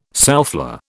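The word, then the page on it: soufflé: British English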